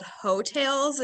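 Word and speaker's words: Heaux Tales: And the name of the album is called Hotails. It's spelled H-E-A-U.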